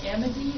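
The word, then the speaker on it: Amedee: One is to reissue waste discharge requirements and NDES permit for the AMIDI, is that how you say it? – Mm-hmm.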